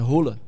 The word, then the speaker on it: Haaland: Sabia como é que é a pronúncia do nome dele? – Sim. – É Rola. – Rola? – É. – Rola de rola? – Isso é rola. Ah, quem falou foi o norueguês, então eu não vou discutir com eles. – Qual foi a pronúncia do nome? Rola.